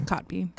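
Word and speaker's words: Kotb: How do you pronounce her last name? – Cotby? – It's Cotby. – Cotby.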